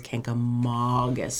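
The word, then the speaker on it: Kancamagus: There is no N, there's no extra. You need to pronounce it the way it is, Kankamangus.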